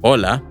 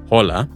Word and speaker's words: hola: Be aware, however, that because the H sound is silent in Spanish, remember it's hola, not hola, it can be tricky to distinguish the two consecutive A vowels between voya and hacer.